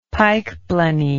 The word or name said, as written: pikeblenny